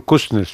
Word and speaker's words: Kuznets: Quizá siguiendo los pasos del inventor y del creador del PIB y de la contabilidad nacional, el señor bielorruso Kuznets, ¿se pronuncia bien así? – Simon Kuznets, sí.